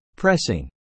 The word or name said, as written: pressing